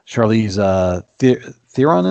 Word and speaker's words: Charlize Theron: It has Charlize Theron in it.